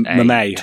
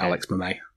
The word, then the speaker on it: Mayi: Mayi, I think it's pronounced. Yeah, I listened to one of Alex Steele's press conferences in preparation, and that's how he pronounced it, Alex Mayi.